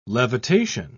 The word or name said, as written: levitation